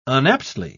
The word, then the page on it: unaptly: British English